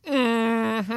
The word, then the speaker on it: Mm-hmm: He has the Phil Hendry Show, and then he has Mm-hmm with Bobby Dooley. – I believe that's pronounced Mm-hmm. Mm-hmm. And Bobby Dooley's actually been on this show.